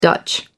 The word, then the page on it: dutch: American English